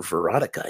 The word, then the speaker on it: Veronica: Is it Veronica or Veronica? I don't remember. But we're going to take a look. It says it right here. It is Veronica. So I was saying it right.